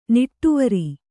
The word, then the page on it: ನಿಟ್ಟುವರಿ: niṭṭuvari